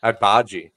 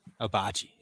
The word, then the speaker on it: Agbaji: Agbaji. – Agbaji.